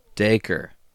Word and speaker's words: Dacre: Daker. – Huh. – That's the only trivia thing, and did you know? His first name pronounced Daker.